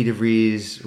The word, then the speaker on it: DeVries: And then Vicky goes, I would like it signed to Vicky DeVries, Rhymes with Cheese.